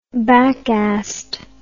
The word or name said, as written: backassed